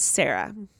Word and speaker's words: Sarah: My name is Sarah. Sarah with an H.